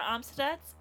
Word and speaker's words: Amstutz: I'm Sandra Amstutz, and all of my social media usernames are at Sandra Amstutz.